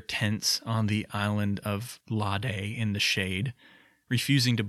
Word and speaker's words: Lade: I do know that the correct pronunciation of the island's name is Lade, but there is a small, maybe immature part of me that wishes it was Lade, so that kind of stupid rhyme above would work out to be "They pitched their tents on the island of Lade in the shade."